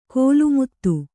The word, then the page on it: ಕೋಲುಮುತ್ತು: kōlu muttu